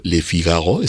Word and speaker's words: Le Figaro: Cuando leí el manifiesto futurista de Filippo Tommaso Marinetti, si mal no recuerdo, él lo publicó en la portada del periódico Le Figaro.